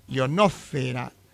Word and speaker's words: ionosfera: De todas ellas, solo la palabra atmósfera es esdrújula. Las demás son graves y llevan el acento sobre la sílaba fe. Estratosfera, ionosfera, biosfera, litosfera o hidrosfera.